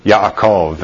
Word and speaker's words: Yaakov: A Hebrew doesn't ever say the sound J. Those are Y's. Okay, so it's Yaakov and Yerushalem. Those kind of pronunciations.